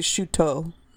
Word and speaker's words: Chouteau: It's spelled C-H-O-U-T-E-A-U. – Mm-hmm. It'd be Chuteau. – Chateau, kind of like Chateau, but that's not, we butcher all of it.